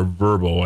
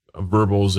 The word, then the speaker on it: VRBO: Similar to Airbnb, but with a narrower focus, VRBO or Verbo, I believe is how they say it, is a place where you can rent out your property as a vacation home.